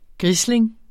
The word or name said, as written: grisling